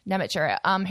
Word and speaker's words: Namatjira: So I think it was announced yesterday that Vincent Namatjira, I'm sorry if I pronounced that wrong. – Namatjira,